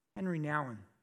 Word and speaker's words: Nouwen: Henri Nouwen,